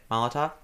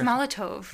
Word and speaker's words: Molotov: That's how you say it? Molotov? – We're having trouble today. I think it's Molotov. – No, it's not Molotov.